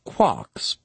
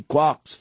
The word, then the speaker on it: quark: The word quark is supposed to be pronounced like quart, but with a K at the end instead of a T. It is usually pronounced to rhyme with lark.